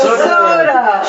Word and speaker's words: Todah: That word, Todah, Lesoda, okay, when pronounced Ashkenazic, is means Lit Todah. Okay? Yeah. Exactly. I told you some things might go over people's heads. – Soda.